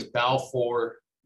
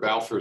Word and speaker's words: Balfour: Uh, my name is Balfour Der. Balfour is spelled B A L F O U R, and last name is Der.